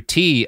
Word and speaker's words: Ti: But right now we're going to continue the story of Andrew T. of God's pluggables. – Oh shit. You know, just go to the Yo Is This Racist podcast. I'm at Andrew T. His last name is spelled T-I everywhere.